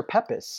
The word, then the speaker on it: Pepys: The Diary of Samuel Pepys, or Pepys, P-E-P-Y-S. – So is it Pepys or Pepys? – It's pronounced Pepys. It's spelled Pepys.